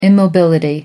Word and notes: immobility: American English

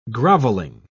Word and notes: grovelling: British English

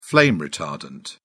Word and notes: flame-retardant: British English

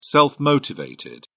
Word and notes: self-motivated: British English

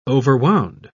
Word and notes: overwound: British English

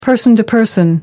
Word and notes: person-to-person: American English

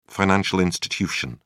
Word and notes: financial institution: British English

